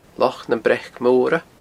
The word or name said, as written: Loch nam Breac Mora